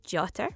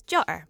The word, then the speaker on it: jotter: I can pronounce it as jotter with a t in the middle, or as jotter with a glottal stop in the middle.